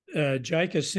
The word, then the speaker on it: JICA: JICA, called JICA, Japan International Cooperation Agency.